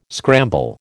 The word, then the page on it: scramble: American English